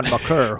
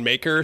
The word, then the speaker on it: Maker: I was thinking about Thon Maker. Thon. Now I don't know how to say his name. – Yeah, I think there was that story that it's pronounced Maker.